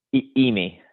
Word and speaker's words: Immi: How do you say Imi? Is that it? Imi ramen. – Imi. – Imi. Okay, everybody listening, Imi Rami, IMMI.